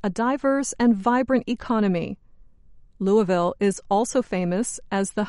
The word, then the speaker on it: Louisville: If you want to sound like a true Southerner, pronounce this city in Kentucky as Louisville, not Louisville, and definitely not Louisville. If you do, they will think you're a Yankee from the North.